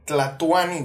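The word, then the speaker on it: Tlatoani: Salvador, el Tlatuani, güey.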